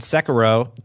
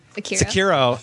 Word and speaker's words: Sekiro: And I added Sekiro to... Did I say it right? – Nope. – Sekiro.